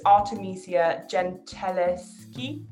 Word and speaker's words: Artemisia Gentileschi: the early 1600s, Artemisia, I'm really bad at pronouncing her name, but it's Artemisia Gentileschi.